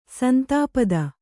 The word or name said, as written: ಸಂತಪ್ತ